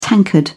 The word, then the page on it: tankard: British English